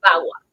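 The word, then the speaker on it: VAWA: Escríbame, por favorcito, agarre su teléfono y escríbame de qué ley estamos hablando hoy día se escribe B chica A W A, Bagua.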